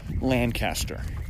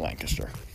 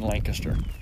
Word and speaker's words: Lancaster: So, just like people not from this area will call where the Bird in Hand is Lancaster, but it's Lancaster. I was talking with a friend of mine. She mentioned, yeah, she got corrected quickly when she said, "Where's Lancaster?" And they went, "You mean Lancaster?" So, yeah, we pronounce it like the Brits.